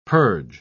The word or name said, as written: purge